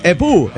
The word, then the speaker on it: epoux: Y se dice epu, esposo. Se escribe epoux, pero se dice epu.